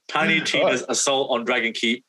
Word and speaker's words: Tiny Tina's Assault on Dragon Keep: Tiny Tina's Assault on Dragon Keep, a Wonderland's one-shot adventure, is coming PS4.